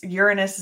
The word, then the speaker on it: Uranus: Now, yes, Uranus is the correct pronunciation. – Yeah, right. – And I emphasize that.